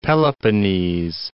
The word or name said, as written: Peloponnese